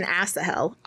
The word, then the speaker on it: Asahel: Okay, so it is Asahel. It's better than Asahel. Asahel, that's better. Okay, so Asahel.